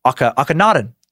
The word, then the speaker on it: Akhenaten: I put the pronunciation guide here, but Akhenaten. There we go. This guy. You mentioned Akhenaten and how he introduced monotheism.